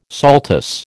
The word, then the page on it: saltus: American English